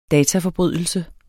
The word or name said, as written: dataforbrydelse